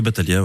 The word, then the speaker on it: Bataglia: Ça vient de Bataglia, qui était le sonneur de cloches. Donc rien à voir. – Oui, Bataglia, oui.